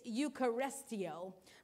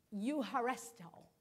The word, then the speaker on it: eucharisteo: In fact, the word thanks in this verse in Greek, it looks like it's eucharistio, but it's eucharisto. Eucharisto is how you say it. And so that's how you pronounce it. But it's spelled E-U-C-H-A-R-I-S-T-E-O.